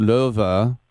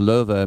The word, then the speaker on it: Löwe: Yeah, it's pronounced Löwe, and so that's why I like Löwenbräu, the Löwenbräu, that's the lion's brew. So L-O-W-E is pronounced Löwe, it means lion.